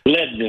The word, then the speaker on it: Leadon: Bernie Leadon, or Leadon, if I'm pronouncing it wrong. But Leadon is correct.